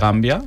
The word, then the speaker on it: Gambia: En la presentación de este viernes, también habrán personas del consulado de Gambia, ¿lo digo bien?